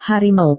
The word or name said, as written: harimau